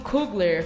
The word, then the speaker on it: Coogler: É, Pantera Negra, que foi lançado em fevereiro desse ano, dirigido por Ryan Coogler. Falei certo? – Aí, agora sim.